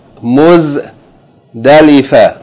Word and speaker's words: Muzdalifa: Muz. Dalifa, Muzdalifa.